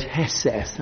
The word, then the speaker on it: hesed: Now, if we start in the Old Testament again, the Old Testament Hebrew for mercy is the word hesed, that's how it's pronounced.